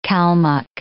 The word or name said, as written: Kalmuk